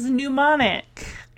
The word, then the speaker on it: mnemonic: Her first word is mnemonic. And what did I tell you guys? There's a silent M.